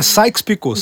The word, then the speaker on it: Sykes-Picot: É Sykes-Picot.